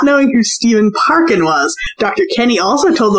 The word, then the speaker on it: Parkin: Kane Dingle and Stephen Parkin. And it's spelled P-A-R-K-I-N. Like, yeah. – like a country song about parking.